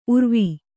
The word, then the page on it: ಊರಾವು: Kannada